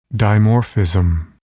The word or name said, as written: dimorphism